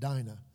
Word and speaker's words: Dinah: And finally, she has a daughter, Dinah, which we would probably call that Dinah, like Dinah Shore, but Dinah is the way that I think it's pronounced in Scripture.